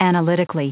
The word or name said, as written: analytically